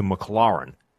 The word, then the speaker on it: McLaurin: It's a cool sounding nickname, except I'm led to believe they're saying that because there's an F1 team called McLaren, which is completely different sounding to McLaurin. Like it's a whole different vowel sound.